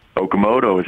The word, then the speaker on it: Okamoto: Hello, is this Brett Akamoto? Am I saying it right? – Okamoto, sure.